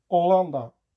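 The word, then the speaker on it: Holanda: Hollanda. Je hoorde me de H niet uitspreken in de laatste vier woorden. De H spreek je namelijk in het Spaans nooit uit.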